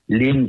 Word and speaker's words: Lyme: Lyme qui peut se prononcer en anglais Lyme, euh, en l'honneur d'une ville du Connecticut, là où les premiers symptômes de la maladie sont apparus.